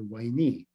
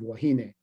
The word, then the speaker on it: Wahine: And why are they calling her Wainee? After I learned a little bit more about how they represented the Hawaiian language in English at this time, I realized that it was very likely an Englishman's pronunciation of Wahine and I became fascinated by her.